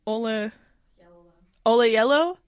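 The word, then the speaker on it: Oyelowo: David, how do you say his name? Ola Yellowo? – Yeah.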